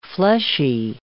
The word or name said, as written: fleshy